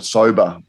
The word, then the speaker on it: Sobah: Actually, with one exception, there's a brand called Soba, which is spelt S-O-B-A-H, which is run by straight out of Boston.